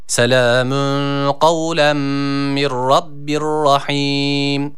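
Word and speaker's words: rahim (رحيم): Rahim kelimesi de rahim şeklinde okunuyor. Yani yine burada mide boşluğundan gelen H olarak okunuyor. Dikkat ediyoruz, burada H harfi yok. H harfi var, esrelidir.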